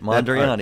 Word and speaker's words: Mondrian: Mondrian.